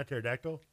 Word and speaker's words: pterodactyl: Pterodactyl. – Pterodactyl. – Is it dough or dull? – Dull. – Either or. – There's an L at the end.